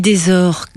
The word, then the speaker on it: Desors: On dit Desors, comme Desors. Trésor.